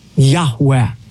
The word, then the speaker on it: Yahweh: In Hebrew, it would be composed of the four Hebrew letters, Yud-Heh, Vav-Heh, which most Semitic scholars believe is pronounced a breathy Yahweh.